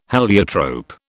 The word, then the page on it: heliotrope: British English